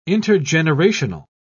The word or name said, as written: intergenerational